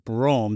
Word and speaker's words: Brougham: The first car you put in here is this 1986 Cadillac Fleetwood Brougham. Did I say that right? – That is correct.